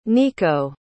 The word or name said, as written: nikko